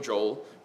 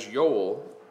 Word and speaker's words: Joel: So we pronounce the name of this book Joel, and that's Latin. The Hebrew word for that is Yoel, which means Yahweh is God.